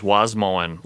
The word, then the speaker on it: Wasmoen: My dad used to say, he told a receptionist once, my name is Wasmoen, like Wasmoen the lawn.